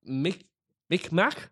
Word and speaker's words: Mi'kmaq: Mi'kmaq, MIKMAQ.